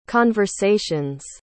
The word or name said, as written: conversations